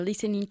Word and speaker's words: listening: I prefer listening to classical music. – Yeah, listening. Dos sílabas, no más. – Listening. – Estás diciendo listening. Listening, two syllables.